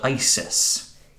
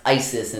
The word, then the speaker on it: Iziz: I think it should be pronounced Iziz, based on the spelling, but everyone seems to call it Isis. – Yes. This was before the rise of the terrorist group in our real world. But yeah, I-Z-I-Z is pronounced Isis in this, so whenever we say Isis, I guess you know what we're talking about.